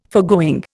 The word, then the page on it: foregoing: Indian